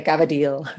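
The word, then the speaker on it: cavatelli: Also, you drop the last syllable of anything that's Italian, right? The cavadil.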